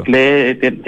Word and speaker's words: Klee: Aprendí claro. A pronunciar Klee gracias a usted. Yo antes siempre decía Paul Klee.